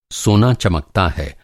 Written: चमकना

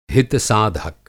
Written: हितसाधक

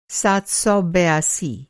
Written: सात सौ बयासी

